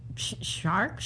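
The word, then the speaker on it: Szwarc: Geno Svark. Mm-hmm. A R C. How would you say that? – S. Say it. Spell it again. – S Z V A R C. shark? shark? Shark. – Yep.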